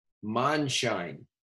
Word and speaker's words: Mondschein: Monshine.